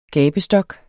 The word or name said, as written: GABESTOKKEN